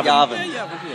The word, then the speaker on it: Yavin: Or Yavin, Yavin? What's the actual pronunciation? – Yavin. – Yeah, Yavin, yeah.